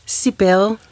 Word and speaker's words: Cybele: Cybele, udtaler du det sådan på... – Ja, altså faktisk siger jeg Cybele, fordi jeg har lavet en blanding af to mytologiske figurer. Den ene, det er Cybele, en græsk gudemoder, og den anden, det er Cybele, som er et orakel.